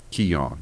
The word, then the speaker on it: Kyong: K-Y-O-N-G. – Keong, okay.